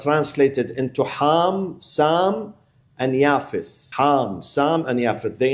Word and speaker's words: Japheth: It's written Japheth, but the way you pronounce it is Yafeth, which is the son of Nuh.